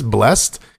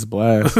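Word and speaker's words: Blxst: I was like, is this Blessed? Because it has an X in the middle. – I thought it was Blissed, but it's Blast.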